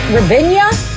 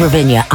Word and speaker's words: Ravinia: Ravinia? – I say Ravinia. I had an extra syllable there.